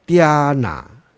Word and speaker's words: Dhyana: Dhyana，那个 H 是没有发音的了啊。